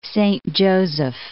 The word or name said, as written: Saint Joseph